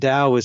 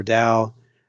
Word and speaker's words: Tao: And Dao is spelled T-A-O. You can pronounce it Tao or Dao.